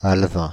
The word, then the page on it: alevin: [al.vɛ̃]; /al.vɛ̃/